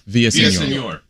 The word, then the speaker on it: Villaseñor: Villaseñor.